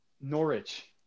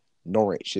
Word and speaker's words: Norwich: I don't know if I can think of another word that has a silent W, but Norwich is one.